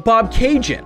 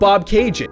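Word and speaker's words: Bobcaygeon: Or what about Bob Cajun? A song that's not even really about Bob Cajun. Gord Downey just needed a word that would rhyme with constellation. Bob Cajun, constellation.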